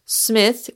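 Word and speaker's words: Psmith: But neither of them wants to do the deed themselves, and that is where Smith, with a silent P, comes in.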